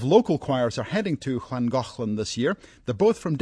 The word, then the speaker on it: Llangollen: It's not spelled like it sounds. It's Llangollen. I called Wales this morning to confirm the pronunciation.